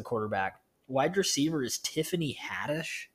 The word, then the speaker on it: Haddish: Tiffany Haddish rhymes with radish.